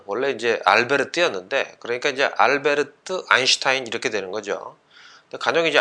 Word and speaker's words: 알베르트: 아무튼 간에 독일 발음으로 이름을 발음을 하면 이제 알베르트 아인슈타인이 되는 것, 이게 아마 보다 더 정확한 발음인 것 같습니다.